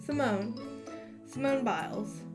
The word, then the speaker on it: Simone Biles: Oh, I was saying it wrong the entire time. Simone Biles.